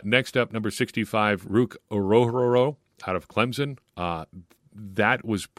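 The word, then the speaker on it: Orhorhoro: But in the official Clemson pronunciation guide, it's Orororo.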